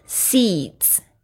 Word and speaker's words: seeds: 就是这个种子 seeds 啊，后面加了这个 s d s 的发音，有很多同学不太清楚怎么发，其实它有一点像儿子的子 seeds you plant。